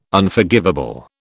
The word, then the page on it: Unforgivable: British English